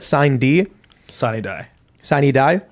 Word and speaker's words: sine die: Sine die.